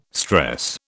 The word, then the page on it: stress: British English